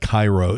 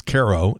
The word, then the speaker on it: Cairo: And I knew about Cairo, Illinois, because, first of all, it's often mispronounced. It isn't pronounced like the one in Egypt. It's not Cairo, it's Cairo.